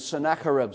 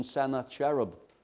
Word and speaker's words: Sennacherib: The 10 tribes in the north are being taken captive by this Assyrian king, and his name is Sennacherib. Some people pronounce him Sennacherib.